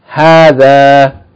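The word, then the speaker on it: Hadha (هذا): This lesson is dealing with hadha, the ism ishara for things which are near. It's pronounced as though there's a long vowel after the first ha. Hadha.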